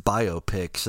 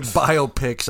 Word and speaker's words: biopic: When people say biopics, I feel like they're emphasizing the wrong syllables. – Right. So I get it. – So I call it biopic.